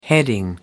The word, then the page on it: heading: American English